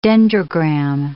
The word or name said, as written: dendrogram